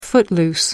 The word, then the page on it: footloose: American English